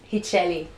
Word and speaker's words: Rithiely: Did I butcher that? – yeah, he did. – And that's like the easy one. Yeah, teach me. – Rithiely. – Rithiely Pereira.